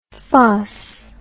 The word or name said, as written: phos